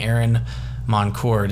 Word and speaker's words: Aaron Moncur: Path has led me to meeting Aaron Moncur. Did I pronounce that right? – Perfect. You got it.